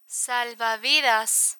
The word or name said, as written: salvavidas